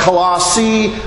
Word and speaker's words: Colossae: You may hear people pronounce it a variety of ways. Maybe I should consult my Greek experts. But Colossae seemed to be the more preferred pronunciation of that ancient city.